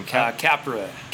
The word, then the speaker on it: Capra: Capra.